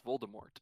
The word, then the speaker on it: Voldemort: And then the audiobooks famously, the before the first movie came out, pronounced it Voldemort, like it French for death. Um, and then the movies came out and established it as Voldemort.